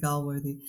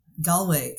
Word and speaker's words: Gallwey: Timothy Galworthy. – Uh, yeah. – Gallwey.